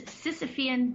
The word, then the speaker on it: Sisyphean: it's like this Sisyphean, did I pronounce that right? – Task.